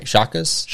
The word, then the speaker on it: Shaka: Shakas? – Shaka.